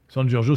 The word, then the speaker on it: San Giorgio: San Giorgio, c'est comme ça se prononce. Hein. C'est euh, San comme Saint en italien et Giorgio comme Georges en italien. G-I-O-R. G-I-O, c'est bien ça euh, édition euh, Le Retour aux Sources, NRBC.